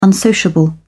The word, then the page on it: unsociable: British English